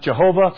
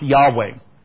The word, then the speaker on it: Yahweh: Scholars used to think it was supposed to be pronounced Jehovah, but recent discoveries have kind of more led them to say no, it should be pronounced Yahweh.